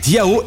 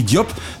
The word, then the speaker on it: Diao: J'aurais donc dû prononcer Camille Diao et non Camille Diop, ma quasi-cécité ayant parfois tendance à me jouer de vilain tour.